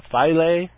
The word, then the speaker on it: Philae: And they have a lander called Philae. Philae, that would be the Latin pronunciation. It's spelled as if Latin.